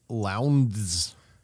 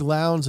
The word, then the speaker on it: Lowndes: There was also a new generation of drivers, including Mark Skeif and Craig Lowndes. – Rhymes with clowns.